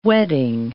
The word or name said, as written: wedding